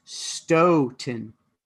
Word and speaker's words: Stoughton: DN user DN, like Nancy I777 said it's Stoughton, pronounced S-T-O-E 10.